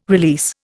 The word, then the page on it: release: Indian